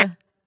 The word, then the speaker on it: Drish: The Drish. Am I saying that correctly? Drish House in Tuscaloosa? Drish. Okay. Mm-hmm.